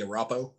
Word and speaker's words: Arapaho: It's from the, I'm going to mispronounce the tribe name, and if you are from this tribe, I'm so sorry, but it's the I will say the Arapaho, Arapaho tribe. – Oh, the Arapaho.